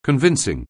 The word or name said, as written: convincing